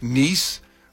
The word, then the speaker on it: Nice: You know what's funny is Monaco is right next to, I know it's pronounced Nice, but it said Old Nice, and I just thought like Old Nice.